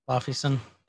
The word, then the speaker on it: Laufeyson: Am I saying it right? – Laufey's son. – Laufeyson. – Uh-huh.